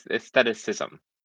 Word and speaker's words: aestheticism: I always mispronounce this. He starts with aesthetic. Okay, someone help me here. – Aesthetics? – Aestheticism. Aestheticism. Oh, okay. There we go.